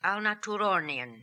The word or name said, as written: Alnatoorornian